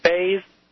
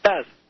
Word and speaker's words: Faz: Mispronouncing is the most common because it's a lot of people say Faz or Faiz or Faz. – Mm-hmm. – But it's Faz like Jet.